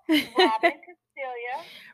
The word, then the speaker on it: Robin Castiglia: And so this is Robin Castella. Did I say that right? Castiglia. – Robin Castiglia.